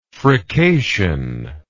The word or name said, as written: frication